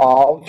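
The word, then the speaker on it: Av (אב): Uh, the AB is Ab or Av. That's how you pronounce it in Hebrew.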